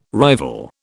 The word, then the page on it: rival: British English